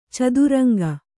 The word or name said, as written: ಚದುರಂಗ